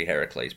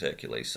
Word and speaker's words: Heracles: Well, Hercules is the Roman pronunciation. – Yeah. – And, you know, they're not doing the Roman myths, they're doing the Greek myths, so it should technically be Heracles, but everyone knows it as Hercules, so what are you going to do?